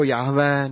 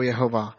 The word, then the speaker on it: Jahve: A v křesťanských církvích se tohle jméno vokalizuje jako Jahve nebo Jehova.